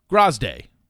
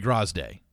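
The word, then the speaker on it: Graziadei: his last name is pronounced Grazday. That's how he said, I'm Joey Grazday.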